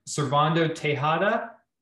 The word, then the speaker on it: Cervando Tejeda: NBA agent Cervando Tejeda.